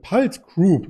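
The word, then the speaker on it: PulteGroup: Und da gibt es einen Player in dem Bereich, der heißt Palt Group. Ich hoffe, das habe ich jetzt richtig ausgesprochen. – Ja, genau, richtig.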